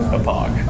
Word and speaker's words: Hauppauge: Oh, is that how you say it? Hopage? – Hopage.